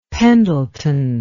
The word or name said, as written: Pendleton